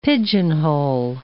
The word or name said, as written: pigeonhole